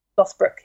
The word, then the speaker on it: Lothbrok: Lothbrok, yeah.